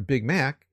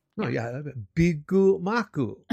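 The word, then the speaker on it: Bigu maku (ビッグマック): Because you can't go to McDonald's and ask for a Big Mac. You're going to get the same look that the waitress gave Paul. – Yeah. – Bigu maku. And you have to say it that way.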